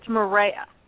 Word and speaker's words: Maria: Can I ask you, is it Maria with an A at the end, or is it Marie? – It's Maria.